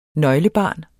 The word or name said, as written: nøglebarn